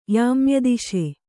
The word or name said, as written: ಯಾಮ್ಯದಿಶೆ